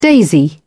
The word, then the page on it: daisy: British English